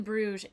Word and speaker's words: Bruges: But yeah, so we are going to be in Bruges, and the Dutch / Flemish pronounce it Brugge, and the French pronounce it Bruges. And I would say Bruges is the most common pronunciation that most people would hear.